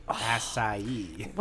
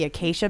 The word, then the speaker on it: acai: Acai berry. Acai. – When I did the event here, I kept calling it the acacia berry because I was just reading it off the.